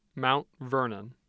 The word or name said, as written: mount vernon